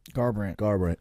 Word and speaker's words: Garbrandt: But I said Gambrant, but how do you pronounce his last name? – Garbrandt, yeah.